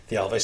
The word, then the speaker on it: Thialfi: Thialfi.